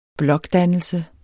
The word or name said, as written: blokdannelse